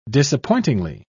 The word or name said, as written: disappointingly